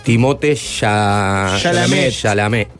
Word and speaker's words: Timothée Chalamet: Yo creo que tiene chances serias de ganar el Oscar, Timothy. – Es francés, se pronuncia Timothée Chalamet, que es el actor que protagoniza a Dylan en sus primeros años.